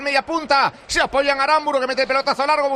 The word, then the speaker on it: Aramburu: Real Sociedad, porque ha comenzado el partido, lo hace por mediación de Zubeldia, que busca un apoyo arriba, maneja desde atrás Aramburu, que así me ha dicho Marco, que se correcto pronuncia, no Aramburu, sino Aramburu.